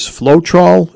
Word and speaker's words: Floetrol: The latex version is Floetrol. Am I saying that right? – Correct.